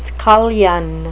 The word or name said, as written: scullion